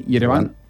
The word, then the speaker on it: Yerevan: Yerevan.